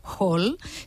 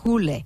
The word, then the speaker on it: Hole: Si vosaltres, com jo, sempre l'heu anomenat Hall, sapigueu que en noruec i en danès es pronuncia Hule.